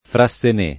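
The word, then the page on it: Frassené: italiano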